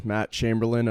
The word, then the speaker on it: Chamberlain: But today we have our guest, Matt Chamberlain of Diamond Hitting Development.